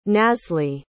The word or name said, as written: nasli